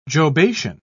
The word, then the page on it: jobation: British English